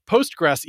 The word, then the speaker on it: Postgres: And yes, I am saying that correctly. We watched a French gentleman say it on YouTube just moments ago. It's a Postgres, even though it's always spelled Postgres with the SQL at the end of it, which makes you want to say PostgreSQL or something.